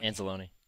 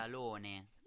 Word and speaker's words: Anzalone: I think it's Anzalone. – Anzalone. – Let me hear it again. – Anzalone.